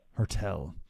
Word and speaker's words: Hertel: Hurdle. How do you pronounce his name? – Hertel.